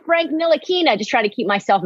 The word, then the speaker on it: Ntilikina: He basically gave the defensive assignment on Trae Young before the halftime to Frank Ntilikina, which I've taken 3 years to learn how to pronounce his last name. So Frank Ntilikina.